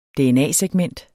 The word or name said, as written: dna-segment